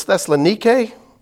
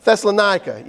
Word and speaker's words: Thessalonica: Oh, but I meant to say, whenever I say the word Thessalonica, I'm always thinking about the Greek pronunciation. In Greek, it's Thessalonike. That's the way that's spelled. But in the South, we say it Thessalonica.